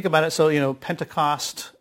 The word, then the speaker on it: Pentecost: The first one is Pentecoste, which is how you pronounce it in the Greek, but it is Pentecost, of course.